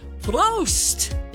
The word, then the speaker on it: Prost: Well, if you want to do it correct, you'll raise the glass and say, Prost! Yeah, Oktoberfest kicking off in Obergatlinburg, Bavarian style. – Please spell it. – P-R-O-S-T.